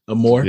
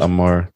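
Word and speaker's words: Amour: Amor. Am I pronouncing that right? Amor. – Yeah. – 2012. – Amor.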